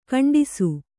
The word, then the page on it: ಖಂಡಿಸು: khaṇḍisu